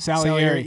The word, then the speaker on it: Salieri: Salieri.